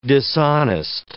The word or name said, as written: dishonest